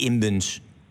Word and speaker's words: Imbens: Er blijft ongeveer 250.000 over voor de familie Imbens. Zeg ik dat goed? – Ja, dat klopt.